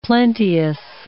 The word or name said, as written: plenteous